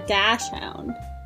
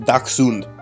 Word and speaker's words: dachshund: Well, because I have gone my entire life saying a dash hound. – That's so wrong. – And everybody... I have heard that more before, though. Like, there must have been a reason why I thought that was right. – A miniature dash hound? Where do you get hound? – Dachshund. – It's pronounced dachshund.